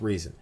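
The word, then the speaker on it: Rezin: I like resin because it's R-E-Z-I-N, and it's just how you pronounce that word, but some people say it's pronounced reason.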